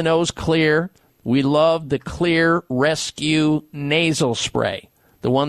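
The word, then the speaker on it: Xlear: Clear is spelled with an X, X-L-E-A-R, rescue nasal spray.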